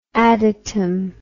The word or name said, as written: adatom